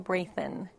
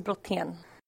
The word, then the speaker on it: Brayton: So in English, it's Rachel Brayton. I've been Rachel almost my entire life, but in Swedish, it's actually pronounced Rakel. Last name in Swedish is Brotén.